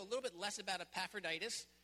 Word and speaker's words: Epaphroditus: And we read about Epaphroditus, five syllables long, nice long name, in Paul's letters to the Philippians, as also the Colossians and Philemon.